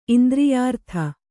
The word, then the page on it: ಇಂದ್ರಿಯಾರ್ಥ: indriyārtha